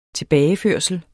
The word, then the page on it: tilbageførsel: -ˌføɐ̯səl